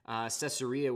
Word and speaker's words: Caesarea: Caesarea, which basically it's spelled Caesar with an E-A on the end, Caesarea was the name he gave it.